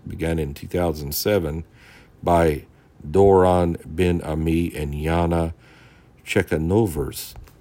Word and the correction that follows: Chekhanovets: Let me make sure I said that name right. Chekhanovets. Chekhanovets. Sorry for mispronouncing that.